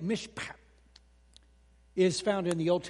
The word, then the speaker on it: mishpat: It's mishpat. Now you don't have to like that so much, but you got to spit a little bit. Mishpat, okay. So M-I-S-H-P-A-T, if you're trying to spell it. People always ask me, how do you spell that? Well, there it is. M-I-S-H, mish, just like it sounds, mishpat.